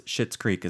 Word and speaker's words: Schitt's Creek: Well, because it's spelt, before we say it, it's spelt S-C-H-I-T-T. So it's Schitt's Creek is the name of the show.